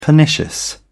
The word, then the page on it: pernicious: British English